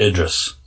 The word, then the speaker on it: Idris: It's just pronounced Idris.